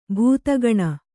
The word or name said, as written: ಭೂತಗಣ